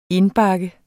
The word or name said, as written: indbakke